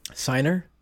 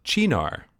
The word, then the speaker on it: Cynar: Remember when we looked up how to say C-Y-N-E-R or C-Y-N-A-R? – Cynar? – Yeah. – Cynar? – That's not how you say it. – How do you say it? – Chinar.